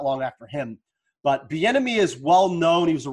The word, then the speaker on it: Bieniemy: Eric, the enemy, Bieniemy. – That's how you say it.